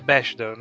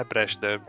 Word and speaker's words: Bechdel: É Bechdel, né? Bechdel. É, tinha um R a mais, mano.